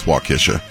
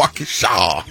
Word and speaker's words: Waukesha: It's Waukesha? – Waukesha. – Waukesha? Kisha? – Kish. – So we were both wrong? – Kisha. – Kisha. – Kisha. – Gotta say it like that? – Yeah. Waukesha. Wisconsin.